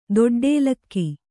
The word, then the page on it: ದೊಡ್ಡಲಕ್ಕಿ: doḍḍa lakki